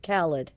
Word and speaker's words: Khaled: Like salad, Khaled.